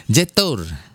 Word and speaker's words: Jetour: Cetor. – Apa? Cetor. – Cetol bukan. Jetor. – Jetor. – Jetor. – Oh, Jetor. – J-E-T-O-U-R. Jetor. – Iya, Jetor.